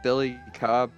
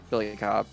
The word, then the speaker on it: Billy Cobb: Just look up my name, Billy Cobb. It's not that hard to spell, C-O-B-B.